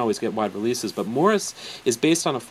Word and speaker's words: Maurice: The film is called Morris, and it's spelled like Maurice, but it's actually pronounced Morris.